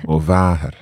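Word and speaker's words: mo mhàthair: So you need to emphasise that long A there. – Mo váthair.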